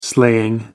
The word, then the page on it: slaying: American English